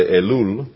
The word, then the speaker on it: Elul: The 25th day of the month, Elul, I don't know how you say it, we would say Elul in Spain.